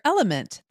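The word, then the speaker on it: LMNT: Sarah, we're welcoming our sponsor, Element. That's spelled L-M-N-T, a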